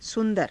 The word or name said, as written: su^dara